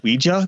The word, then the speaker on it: Ouija: and it dates everything from the earliest of Ouija boards. I keep saying Ouija because it's actually pronounced Ouija, not Ouija, right? – Like how we do it.